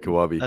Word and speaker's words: Kawabe: Kawabe.